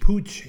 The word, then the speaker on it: Puig: Dr. Manel Puig, spelled P-U-I-G, but pronounced Pooch in Catalan, is a leading endocrinologist in Barcelona and now Pituitary World News friend and contributor.